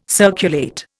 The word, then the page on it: circulate: Indian